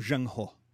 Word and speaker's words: Zheng He: We see this tension in the voyages of exploration of Zheng He, which is spelled in English Z-H-E-N-G, and his last name, H-E, Zheng He.